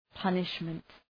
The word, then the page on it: punishment: ‘pʌnıʃmənt